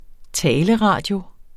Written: taleradio

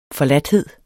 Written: forladthed

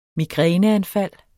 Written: migræneanfald

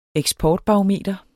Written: eksportbarometer

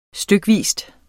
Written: stykvis

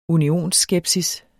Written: unionsskepsis

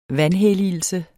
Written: vanhelligelse

